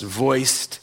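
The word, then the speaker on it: Wuest: Kenneth Wiest, I think his name is pronounced Voist. He's German.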